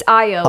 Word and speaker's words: Ayo: It's Ayo. Ayo.